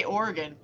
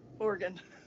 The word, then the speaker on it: Oregon: Say Oregon. – Oregon. – Oregon.